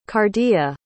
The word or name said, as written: cardea